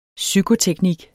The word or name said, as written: psykoteknik